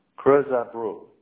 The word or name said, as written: Creusabro